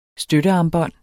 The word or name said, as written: støttearmbånd